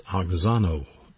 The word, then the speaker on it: auxano: It's pronounced auxano, A-U-X-A-N-O, auxano.